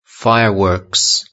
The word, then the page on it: fireworks: British English